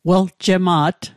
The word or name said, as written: weljema't